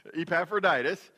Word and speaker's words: Epaphroditus: Now this is a word we're going to be saying a lot, so turn to your neighbor and say Epaphroditus. You said it wrong. Epaphroditus. So that's the one that word we're going to be using a lot today. And if I'm saying it wrong, we're all saying it wrong. So it's just the way that I think it should be.